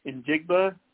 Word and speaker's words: Njigba: Yeah, I think it's Njigba. – Yeah. – Okay, thank you. – Yeah, after missing Silent J. – Okay, got it. Silent J, the ever famous Silent J.